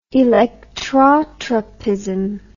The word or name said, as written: electrotropic